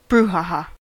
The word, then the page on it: Aufruhr: /ˈaʊ̯fˌʁuːɐ̯/